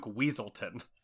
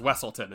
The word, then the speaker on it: Weaselton: So he even is in Zootopia as a character named a weasel named Duke Weaselton, everyone mispronounces as Wesselton in that movie.